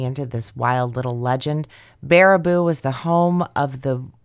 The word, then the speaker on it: Baraboo: My latest local to me legend, the paranormal pachyderms of Baraboo, Wisconsin, pronounced Baraboo.